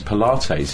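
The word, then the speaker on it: Polites: The CEO of Jaguar Land Rover is a guy called Jeff Pilates, I never know how to pronounce Pilates. – His name. Pilates, that's right.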